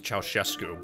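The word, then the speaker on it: Ceaușescu: Which wasn't very long, because it was only 3 days after Gigi's death that Ceausescu, it's actually pronounced Ceausescu, but I'm saying Ceausescu because I'm used to it now, officially took power as the General Secretary of Romania.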